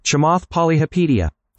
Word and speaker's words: Chamath Palihapitiya: I think it's Kamath Palihapitiya. Let me see. I'm going to do this with you. – Chamath Palihapitiya.